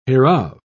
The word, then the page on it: hereof: British English